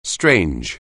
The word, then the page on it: strange: anglais